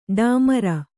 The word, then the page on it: ಢಮಾರ್: Kannada